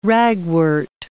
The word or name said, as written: ragwort